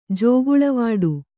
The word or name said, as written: ಜೋಗುಳೆವಾಡು